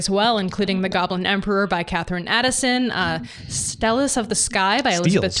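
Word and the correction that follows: Steles: Steels, I think, is how you say that. Steels. – Yeah, Steels is like a big pillar, I think.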